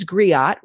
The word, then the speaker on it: griot: It says griot. Is that the correct pronunciation of that, Betty? Great. So she is her family's griot, which means oral historian.